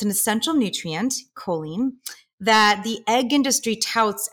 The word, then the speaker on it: choline: So my question for you, first and foremost is, what is choline? And am I pronouncing it correctly? – Yes, you're pronouncing it perfectly, Sanford, of course.